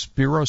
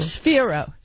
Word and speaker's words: Sphero: Sphere is it pronounced Spiro? Spiro? Spiro? – Sphero. – Sphero. Sphero. Like think of a sphere. – Right. Like sphere. the world is a sphere. Got so it. So it's sphere O.